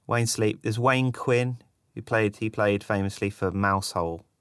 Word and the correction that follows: Mousehole: It's pronounced Mousel.